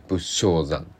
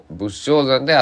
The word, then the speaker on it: 仏生山: 仏が生まれる山と書いて仏生山と読みますだから仏のアスパラ大騒ぎ仏生山